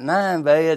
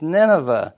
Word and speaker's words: Nineveh: And he sits down in my lap, and I said, God told Jonah to go to Nineveh. And he looked at me, and he said, it's not Nineveh, it's Nineveh.